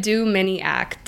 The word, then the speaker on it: Dominiak: Think of it Dominiak. Dominiak.